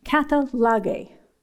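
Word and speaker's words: katallage: The Greek word here for atonement, I looked it up in the Helps Word Studies, and it's a Greek word pronounced katalage.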